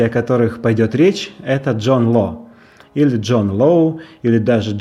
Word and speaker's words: Джон Ло: поэтому правильнее будет произносить его фамилию так, как она произносилась во Франции – Джон Ло.